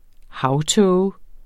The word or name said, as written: havtåge